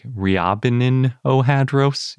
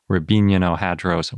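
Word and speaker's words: Riabininohadros: Its name is Rabinian Ohadros, and it's spelled kind of like Riabinin Ohadros, if you see it written, in case you want to recognize it. But I looked up the Russian pronunciation, and I'm pretty sure it's more like Rabinian Ohadros.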